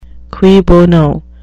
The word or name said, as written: cui bono